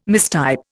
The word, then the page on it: mistype: Indian